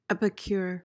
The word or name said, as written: epicure